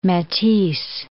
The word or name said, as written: Matisse